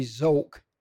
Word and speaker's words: Zok: But Walter and Judy Zok, Z-O-K. I pronounced it right.